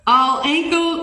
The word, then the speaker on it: Alankyle: How you say it? All Ankle, A-L-A-N-K-Y-L-E.